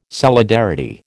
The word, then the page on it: solidarity: American English